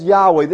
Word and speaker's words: Yahweh: It's Yod Heh Vav Heh in the Hebrew. It's pronounced Yahweh.